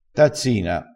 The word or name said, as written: tazzina